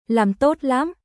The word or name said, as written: Làm tốt lắm